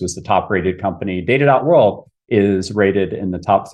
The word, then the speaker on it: Data.World: It's Data.World, by the way. Yep. I always pronounce it that.